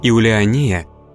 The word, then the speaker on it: Иулиания: В современном русском языке имя Иулиания произносится так же, как Ульяна или Юлиана.